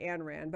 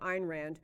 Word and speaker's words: Ayn Rand: This is by Ayn Rand, or you might say Anne Rand, but I think she pronounced it Ayn Rand.